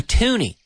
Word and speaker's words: toonie: But the Canadian $1 coin is the slang, jerga, slang term for that coin, is a toonie. Just because it rhymes with loony.